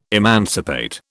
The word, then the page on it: emancipate: British English